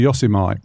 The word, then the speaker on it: Yosemite: You know, when I first started in radio, I used to pronounce things wrong, because Marmite's Marmite, isn't it? And that should be Yosemite.